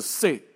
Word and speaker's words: See: 湖在德文里面的发音叫做 See 啊，S E E，但是念作 See。